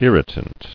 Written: Irritant

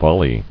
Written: Volleys

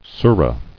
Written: Surah

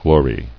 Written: Glorying

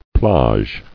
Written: Plage

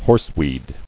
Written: Horseweed

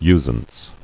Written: Usance

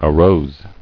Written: Arose

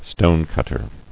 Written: Stonecutting

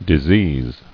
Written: Diseasing

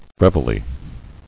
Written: Reveille

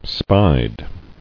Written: Spied

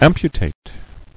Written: Amputator

